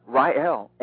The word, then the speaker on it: Rael: Rael. And you know, usually people don't pronounce it right.